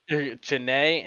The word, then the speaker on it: Chiney: Chine and Nika, where things, you know, feels like they should be having better. Sorry, did I say that wrong? – Chiney and Nick.